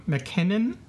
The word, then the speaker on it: Mackinnon: no stranger to Doctor Who and directed by Douglas McKen- um, Mackinnon. I'm pronouncing his last name pro- properly.